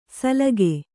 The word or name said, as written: ಸಲಾಖೆ